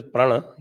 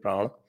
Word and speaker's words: Prana: Actually, it is Prana. He keeps pronouncing it Prana.